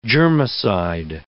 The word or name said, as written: germicide